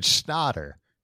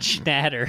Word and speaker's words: Schnatter: Like, yeah, apparently he pronounces it Schnatter. – Yeah, not Schnatter. – Yeah, which is like, why would you want it to sound like snot? – Why would you want to sound like shat?